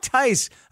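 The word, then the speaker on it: Thaiss: Now, Matt Tice, I believe it's pronounced Tice, it's T-H-A-I-S-S, is not a good hitter.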